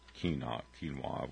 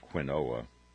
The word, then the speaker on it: quinoa: I kept calling it quinoa, and my son corrected me with a disdainful look in his eyes. It's quinoa, Dad, so don't embarrass me anymore.